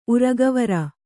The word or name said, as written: ಉರಗವರ